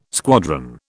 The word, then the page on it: squadron: British English